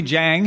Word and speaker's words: Jiang: So we're at the Nebulas, and Emily Jiang has joined us.